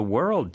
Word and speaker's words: world: That means the world to me. – 자, 발음하기 어려운 단어. – World. W-er-old. Ignore the O. – 맞아요. – W-O-R-L-D.